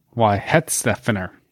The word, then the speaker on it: Weihenstephaner: Well, I would pronounce this as Weihenstephaner. – Well, that's what it is.